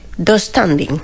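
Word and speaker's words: döstädning: Yo lo pronuncio... Do standing. – Sí, así. Tengo esa silla del Ikea. Sí, pronuncia así. Sí, ¿verdad? – Sí.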